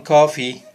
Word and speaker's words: Coffey: También pues conocimos a este hombre llamado John Coffey, el prisionero, cuyo nombre se pronuncia igual que la bebida, pero que se escribe diferente.